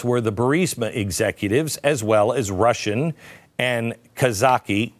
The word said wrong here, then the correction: Kazakh: How do you say it? Kazakh oligarchs. – Kazakh. – That's right. Kazakhstan.